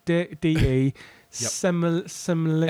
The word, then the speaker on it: De Semlyen: So it's Nick, N-I-C-K, D-E, Semlyen. – S-E-M-L-Y-E-N.